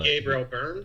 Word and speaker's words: Gabriel Byrne: Starring Toni Collette and Gabriel Byrne. Am I pronouncing that right? I mean, I'm terrible with names, but Gabriel Byrne. – Yeah. That's Gabriel Byrne.